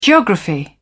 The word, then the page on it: geography: British English